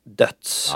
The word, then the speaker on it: Deutz: var av märket som stavas D-E-T... Nej, förlåt. D-E-U-T-Z. – Ja. – Jag har lärt mig att det uttalas döds. Ja, det har jag också hört.